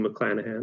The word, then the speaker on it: McClanahan: played by Rue McClanahan. Did I say her name right? – Yes.